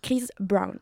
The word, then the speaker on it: Chris Brown: Attends, tant qu'on est dans la prononciation, c'est Chris Brown, c'est pas Chris Brown. Hein. – Oh, mais moi, j'aime... C'est Cynthia Brown. – Bah, c'est comme un brownie, tu vois.